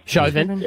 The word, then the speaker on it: Chauvin: Derek Chauvin, I think is how you pronounce it. – Chauvin.